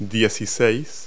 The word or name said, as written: sixteen